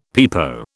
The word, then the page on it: pepo: British English